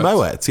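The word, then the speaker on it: Moët: Goes against all the sort of things you think about French off of silent T's and all of that. – Yeah. – But yeah, it's actually pronounced Moet.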